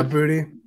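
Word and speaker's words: Boutte: Yeah, Booty.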